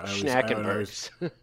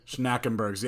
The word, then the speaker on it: Schnackenberg's: Yeah, so the place that replaced, oh god, you pronounced it correct before. – Schnackenberg's. – Schnackenberg's, yeah, I was always like Schnitzelgruben.